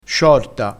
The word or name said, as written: sciorta